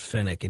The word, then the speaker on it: Fenwick: And I think it's actually pronounced Fennec.